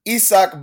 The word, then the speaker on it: Isaac: Isaac Bonga Isak Bonga, excuse me. Uh, we, we, we, learned this week the, the correct pronunciation. Uh, the, his mother pronounces his name as Isak. So his that, that that's his name. His mama called him Isak, so his name is Isak.